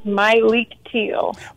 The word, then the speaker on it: Myleik Teele: It is Myleik Teele. – My, with an emphasis on my.